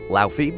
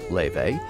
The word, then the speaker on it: Laufey: Her name looks like Laufey, but it's pronounced Laufey.